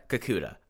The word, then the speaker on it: Kikuta: And I'm stoked to see, like, we haven't really even, like, scratched the surface with someone like Madoka Kotoka. You know, Kikut. I got the name right when I was pronouncing it before. Madoka Kakuta. I think that he has something really interesting to watch.